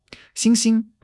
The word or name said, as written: 星星